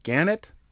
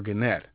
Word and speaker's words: Gannett: Is it Gannett or Gannett? And Newhart, an apocryphal story, supposedly said the emphasis is on the net, as in net income.